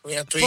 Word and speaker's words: Antoine: Pure la cantava pure uno francese, Antonin. Antoine. – Forse Antoine. – Antoine, scusa. Che pronuncia che c'ho? Antoine, sì. – Antoine, non Antonin.